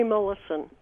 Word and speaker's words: Molaison: How did you get to know this man, Henry Gustave Molaison? Is that how you pronounce his name? – Henry Mollison is the way he pronounced it.